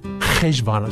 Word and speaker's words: Heshvan: Heshvan, excuse me, the Jews would pronounce it with a ch instead of a.